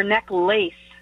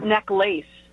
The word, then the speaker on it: necklace: Is it neck lift or neck lace? He said neck lace. It's necklace. – No, necklace is like a lack of neck. Less. – No, you don't pronounce the lace. It's not neck lace.